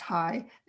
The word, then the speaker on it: HAI: Well, my first relationship with someone I met at, we call it HAI, it's pronounced high. It's easier to say, was again for 2 years with a guy, but it was a very spiritual, sexual, wonderful relationship.